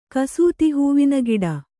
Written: ಕಸೂತಿಹೂವಿನಗಿಡ

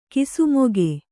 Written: ಕಿಸುಮೊಗೆ

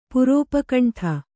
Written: ಪುರೋಪಕಂಠ

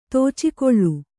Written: ತೋಚಿಕೊಳ್ಳು

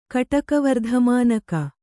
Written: ಕಟಕವರ್ಧಮಾನಕ

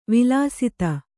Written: ವಿಲಸಿತ

